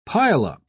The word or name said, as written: pileup